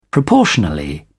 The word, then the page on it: proportionally: British English